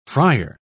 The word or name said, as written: frier